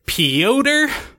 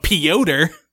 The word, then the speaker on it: Piotr: It's pronounced Piot, but they went with the phonetic pronunciation of Piotr.